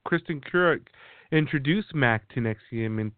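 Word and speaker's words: NXIVM: It's pronounced Nexium.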